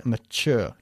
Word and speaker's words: mature: Bien, y se pronuncia mature, con un sonido de la che.